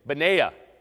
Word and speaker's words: Benaiah: And so as we think about mighty men and Benaiah, and that's pronounced correctly, by the way.